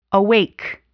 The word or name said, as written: awake